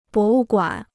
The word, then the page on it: 博物馆: bó wù guǎn